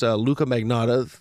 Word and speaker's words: Luca Magnotta: You know what was something that's very interesting about, which I think separates many performers, is this Luca Magnotta. Am I pronouncing his name right? – Yep.